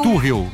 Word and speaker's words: Tuchel: Tuchel, é Tuchel a pronúncia Tuchel, correta.